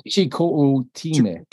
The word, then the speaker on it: Chicoutimi: but I believe it's pronounced Chico. You would know this. Uh, Chi is I think Chicoutimi. – Chicoutimi. – Chicoutimi. – Yeah. Chicoutimi. – Chicoutimi. Yeah. – There you go.